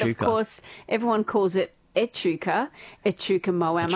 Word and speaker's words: Echuca: my dad was pronouncing Echuca as Yechuca. I was like, what, say that again, Dad? Yechuca. I said, Dad, I think there's a, you're putting a U in there somewhere where it's not meant to be.